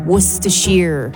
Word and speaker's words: Worcestershire: Worcestershire is how you pronounce it.